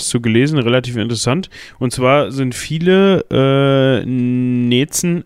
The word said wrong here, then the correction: Nenzen: äh, so spricht man Nenzen, Nenzen Entschuldigung, Nenzen. mit N,